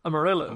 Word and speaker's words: Amarillo: O bueno, dado que, dado que transcurre en la ciudad americana, o en el pueblo americano eh, homónimo, deberíamos pronunciarlo en inglés como lo pronuncian aquí y sería Amarillo. Amarillo. – Amarillo, porque es como lo llaman aquí. Pero aquí, como somos, como somos españoles y sabemos que obviamente es una palabra española, lo vamos a llamar amarillo.